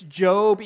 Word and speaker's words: Job: And yes, it's Job, even though it's spelled Job. I don't know why we say Job instead of Job.